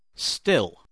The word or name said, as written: still